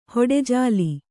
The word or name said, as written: ಹೊಟ್ಟೆಜಾಲಿ